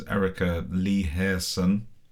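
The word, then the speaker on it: Erica Leerhsen: There's Erica Lee Hairson, Swedish. – Good pronunciation. Didn't know you spoke Swedish.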